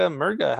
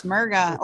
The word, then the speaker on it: Murga: We have with us Rebecca Murga. How's it going? Did I say your name? – Hey, yes, Murga. Or if you are Spanish, it's Murga.